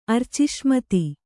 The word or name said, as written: ಅರ್ಚಿಷ್ಮತಿ